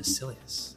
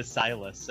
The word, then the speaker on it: Silius: Yep. Silius, Silius, I don't know. You guys used to pronounce it Journey to Silas, and I'm like, oh man.